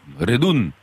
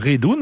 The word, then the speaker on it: redon: Et d'ailleurs, en ancien occitan, le nom commun rond se prononce? – Redun. – Voilà, Redun, mais ça s'écrit comme votre D'accord. nom, R-E-D-O-N.